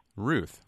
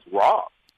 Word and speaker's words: Routh: I wanted to let you know that my grandfather's, one of his family names was R-O-U-T-H, and although the street's certainly not named for him, the O is silent. It's okay. Ruth. Ruth Street.